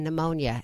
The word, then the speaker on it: pneumonia: We're chatting with Brian Scott Smith, like the silent P in pneumonia, as P.G.